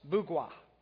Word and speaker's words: Mbugua: The pastor's name is Wallace Bugwa. Put his picture up there, if you will. That's the first picture. And the M, his name is spelled M-B-U-G-U-A, but the M is silent. Okay, we may need to get Laura to help with the pictures there.